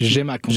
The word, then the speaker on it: GMAQ: Puis tu dis donc que la Grande Mobilisation pour les arts au Québec, d'ailleurs l'acronyme étant GMAQ, comment on le prononce? GMAQ? – GMAQ. – GMAQ, parfait.